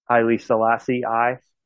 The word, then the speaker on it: Haile Selassie I: The Rastafarians pronounce it as I, like, when they say I and I, that's like the Rastafarian for God. I think that's the reason they pronounce the I for Haile Selassie I.